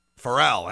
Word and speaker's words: Pharrell: And from the screen cap you can see here, this song features Pharrell Williams, or is it Pharrell? I'll probably pronounce it right. Is it Pharrell? Pharrell, that's what he says.